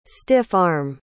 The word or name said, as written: stiff-arm